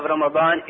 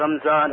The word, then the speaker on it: Ramadan: Ramadan, the ulama mentioned that the word Ramadan, the correct pronunciation of Ramadan is Ramadan. بفتح الميم, meaning with the fatha or the zabar or the nasab of meem. Ramadan. Not as some people say Ramadan or Ramzan or Ramjan.